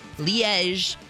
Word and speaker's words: Liège: I think it's pronounced Liège. – No. – It is Liège? – It is Liège, apparently. Okay. And I love my pronouncer is specifically Liège, and you say the Zh sound at the end like Zha Zha.